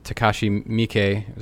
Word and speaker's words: Takashi Miike: You mentioned Takashi Miike. Is that how you say his name? – Yes.